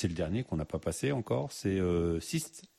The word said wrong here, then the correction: Solstice: Solstice. – Alors solstice. – Ouais, ça se prononce solstice.